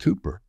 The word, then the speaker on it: Cowper: You'll see it's spelled C-O-W-P-E-R, but it's pronounced Cooper.